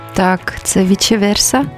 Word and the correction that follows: Віце Верса: точніше «Віце Верса», так воно вимовляється, це латина, тому що я шанувальник Наталі Орейро з 13 років.